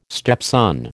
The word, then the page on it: stepson: American English